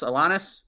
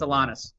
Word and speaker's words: Alanis: Is that how you pronounce it? Correct me now. – Alanis? – Oh, there you go. That's Uncle Sam's pronunciation of last names. We butcher everything. It's Alanis.